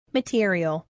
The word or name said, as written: material